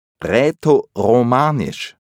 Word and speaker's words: Rätoromanisch: Rätoromanisch. Das ist ein bisschen wie Latein. Rätoromanisch.